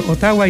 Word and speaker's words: Ottawa: Ottawa. Así se pronuncia. Es que desde chico nos enseñaron en el colegio Ottawa y en rigor es Ottawa.